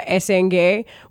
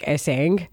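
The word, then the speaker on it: Essengue: 18-year-old Noah Esenge, which, by the way, if you speak a slight bit of French, you're like, where's the accent on the end of the E? Because it looks like Esang.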